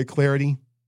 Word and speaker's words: Clardy: Give me a follow on Twitter, at Troy Clarity. Last name is spelled C-L-A-R-D-Y, at Troy Clarity for the follow on Twitter.